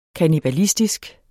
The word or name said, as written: kannibalistisk